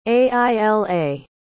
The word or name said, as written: aila